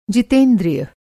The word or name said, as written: जितेंद्रिय